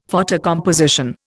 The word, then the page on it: photocomposition: Indian